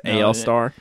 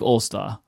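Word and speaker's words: AL-Star: our official pronunciation guy, Independence, is in the chat room. It's AL Star. – I don't care what anybody else says for our show. It's AL Star. All-Star. I don't like All-Star.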